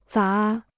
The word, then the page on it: 杂: zá